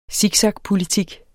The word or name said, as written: siksakpolitik